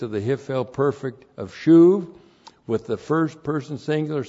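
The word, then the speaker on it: shuv (שוב): S-H long U-B shuv.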